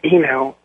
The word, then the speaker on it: Eno: I want to ask Mr. Eno, am I pronouncing his name correctly? – Yes, you are.